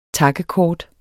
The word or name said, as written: takkekort